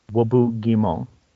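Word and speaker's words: Roboute Guilliman: But Rebute Guilleman, you know, you have to admit it's a French name, right? So in French, it would be pronounced Wobu Guimon.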